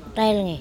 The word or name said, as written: TElngE